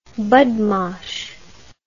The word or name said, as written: budmash